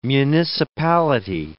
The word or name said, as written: municipality